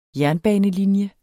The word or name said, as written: jernbanelinje